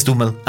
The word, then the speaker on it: Duhamel: Y protagonizada por James Franco, Sarah Gadon, Chris Cooper y Josh Duhamel. Sí, ya aprendí cómo pronunciar el apellido de... – ¿Es Duhamel? – Es Duhamel. Ajá. – O sea, la A no se pronuncia.